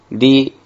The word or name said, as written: ri